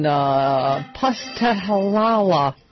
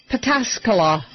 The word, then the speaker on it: Pataskala: Okay, here is a beaver stack from Jennifer in Pustahalala, Ohio. Pataskala, Ohio. I think I said that right.